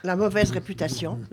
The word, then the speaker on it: La mauvaise réputation: La mauvaise réputation.